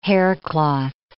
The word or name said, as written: haircloth